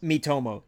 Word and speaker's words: Miitomo: The Miitomo? – Miitomo.